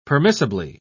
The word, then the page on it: permissibly: British English